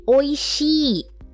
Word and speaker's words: おいしい: For example, how to say the word delicious. So that is oishii. Oishii.